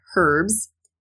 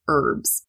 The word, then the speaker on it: herbs: We don't pronounce that H at the front like the Brits do. They would say herbs, but in American English, we say herbs.